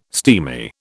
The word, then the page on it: steamy: British English